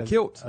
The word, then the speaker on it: kilt: First of all, he had on a, what was it, a quilt? – What, the Mac? – A kilt. A kilt. I said it wrong.